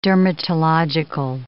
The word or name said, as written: dermatological